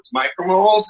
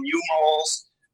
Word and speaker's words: micromole: So the correct way to say U-mole there, it's not a U, it's a micro. It's a micromole.